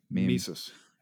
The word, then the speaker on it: Mises: There's a Mises Institute. How do you say that? – Mises.